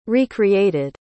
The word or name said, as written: recreated